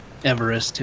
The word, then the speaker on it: Everest: The height of Mount Everest was not calculated by George Everest, but by a brilliant mathematician who has since been all but forgotten. Everest himself, who pronounced his name Everest,